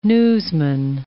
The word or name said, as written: newsman